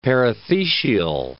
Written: perithecial

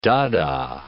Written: Dada

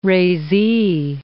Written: razee

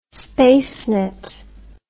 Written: spacenet

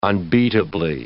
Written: unbeatably

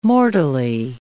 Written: mortally

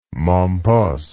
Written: Mompos

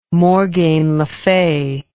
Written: morgain le fay

